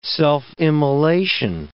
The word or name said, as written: self-immolation